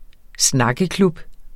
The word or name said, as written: snakkeklub